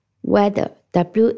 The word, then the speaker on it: whether: weather 这个词和天气的 weather 读音是一模一样的，拼写不一样。听一下拼写：w h e t h e r weather，w h e t h e r weather。